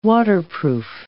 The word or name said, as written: waterproof